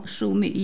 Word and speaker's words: Sumi: Si premia il soprano Sumi, io credo si pronunci così, mi corregga lei. – Sì, ecco. Sì, certo. – Ecco, allora, chi è? Perché non tutti ovviamente conosciamo Sumi Yo.